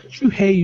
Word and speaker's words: Shuhei: Shuhei. – Yeah. Shuhei Yoshida.